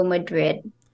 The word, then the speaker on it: Madrid: I only know like one city in Spain, and that's Madrid. So I'm gonna go Madrid.